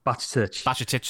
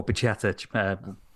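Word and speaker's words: Bajčetić: So you're left now with, obviously, McAllister and Henderson and the young lad who I always butchered the name of. – Bacicic, that's the one. – Yeah. Or Bacicic, you know.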